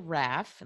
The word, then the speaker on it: Raff: So you can connect on my website, which is my name, Jill Raff. And Raff is spelled R like Robert, A-F-F like French fry.